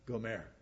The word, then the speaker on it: Gomer: I'm going to pronounce it the Hebrew way. It sounds a lot more feminine. Gomer.